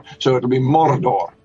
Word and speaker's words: Mordor: And I said, well, young characters like the hobbits will say, as we do, Mordor.